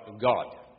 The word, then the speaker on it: Gad: Gad would be pronounced not Gad. Most languages don't have a flat A like Americans have. They have a longer A, so it's odd. It would be God. Except it wouldn't be, the D comes out, especially in Hebrew, as a T, Gott.